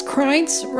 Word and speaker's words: Krajnc: It's Krajnc rhymes with Heinz ketchup.